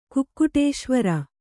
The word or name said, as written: ಕುಕ್ಕುಟೇಶ್ವರ